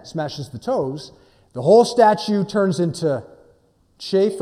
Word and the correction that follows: chaff: or chaff. Never know, how do you say that anyways? Chaff, yeah. Chafing is something, yeah, no, that's different. We're not going to talk about chafing this morning, we talk about chaff.